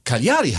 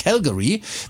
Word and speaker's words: Cagliari: Cagliari heißt's. – Okay, ich hätte gesagt Cagliari, aber bin auch kein Stadionsprecher. – Das habe ich auch immer falsch gesagt. Das heißt ja nicht Calgary, weil man kommt von Calgary auf. Wie hast du es gerade gesagt? – Cagliari, ja. – Nee, ist es aber nicht.